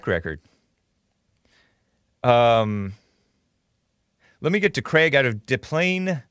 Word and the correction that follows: Des Plaines: Des Plaines, Illinois.